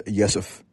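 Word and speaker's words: Rzeszów: I'm currently in Jeszów, which is a city in Poland that frankly took me a while to figure out how to pronounce the name of, but it is Jeszów, but it's spelled R-Z-E-S-Z-O-W.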